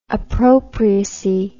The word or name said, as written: appropriacy